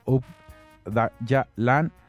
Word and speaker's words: Öcalan: Desde su fundación, el PKK ha sido liberado por Abdullah Öcalan, pronunciado Ubdayalan, aunque sus críticos dicen que Öcalan no repensó su estrategia de guerra popular hasta que fue capturado en 1999.